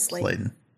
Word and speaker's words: Sladen: Yeah, it is Sladen. – Yeah, I think it's Sladen.